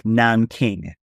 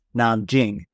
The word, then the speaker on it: Nanjing: And she uses place names like an easy example is this is like Nanking. She uses Nanking in there. Well, the name of the city is pronounced Nanjing. That's how it's written in Pinyin.